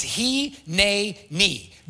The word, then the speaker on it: Hineni: So on this next slide, we have the Hebrew word is he, ne, ni. This is how you spell it, H-I-N-E-N-I.